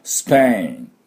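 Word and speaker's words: Spain: では、早速いきたいと思いますが、スペイン、スペイン、スペイン、この音なんですね。で、ダブルシーンがポイントです。スプスプスプスプ、このスプスプスプスプと音を出せないと、このスペインって音になりません。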